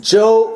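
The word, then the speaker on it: Joël: Joe, because it's got the two dots over the E. Joe, and then it's Robuchon. Okay, that's how you say it.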